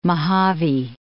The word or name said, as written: Mohave Desert